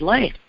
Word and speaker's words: Ley: And then I'm on Twitter at Dr. David Lay. – And your last name is spelled L-E-Y. – L-E-Y, yep.